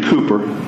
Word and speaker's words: Cowper: His name was William Cowper. It's spelled Cowper. He was a British gentleman. And Cowper.